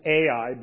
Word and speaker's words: Ai: It is the altar that he built, look at verse 8, between Beth El and I. Sometimes we pronounce it Ai, but in Hebrew this would just be pronounced I.